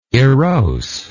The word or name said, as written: erosely